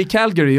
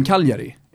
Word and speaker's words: Cagliari: Men om någon säger Calgary om Cagliari, Ja, men ja.